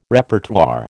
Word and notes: Repertoire: American English

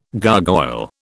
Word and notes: gargoyle: British English

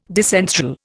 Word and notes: disenthral: Indian

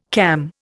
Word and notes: cam: Indian

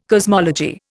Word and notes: cosmology: Indian

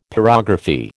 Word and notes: pyrography: American English

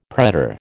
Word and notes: pretor: American English

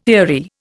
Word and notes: eerie: Indian

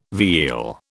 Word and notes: veal: British English

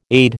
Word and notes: aide: American English

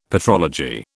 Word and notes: petrology: British English

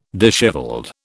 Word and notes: dishevelled: British English

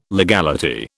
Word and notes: legality: British English

